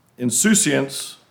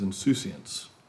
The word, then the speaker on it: insouciance: The title this morning is Insouciance and the Good Life. I know it can be pronounced a different way, but the first preferred way in Merriam-Webster is insouciance. Insouciance.